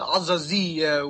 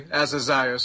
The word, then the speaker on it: Azaziah: In Arabic, you pronounce it Azaziah, which means the light which comforts. But in English, you just say Azaziah, so you're pronouncing it right.